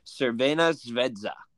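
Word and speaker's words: Crvena zvezda: Man City, RB Leipzig, Young Boys, and I looked this up to make sure I'd be able to pronounce it correctly before we recorded. Cervena Zvezda.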